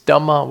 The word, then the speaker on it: Dhamma: This is Dhamma with a long A at the end, plural.